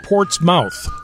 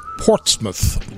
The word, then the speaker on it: Portsmouth: Not Portsmouth. Portsmouth. – But it's spelled like Portsmouth. – Yeah, right. Yeah. P-O-R-T-S-M-O-U-T-H.